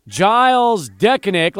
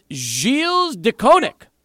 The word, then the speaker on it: Gilles Dekoninck: But yes, so apparently the correct pronunciation is not Gilles Dekoninck, like we've been saying. It's Gilles Dekoninck. How about that?